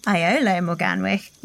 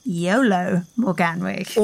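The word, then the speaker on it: Iolo Morganwg: And from Andrew, who helpfully said in response to our Druids episode that rather than Iolo Morganwich, we should be pronouncing his name Iolo Morganwich.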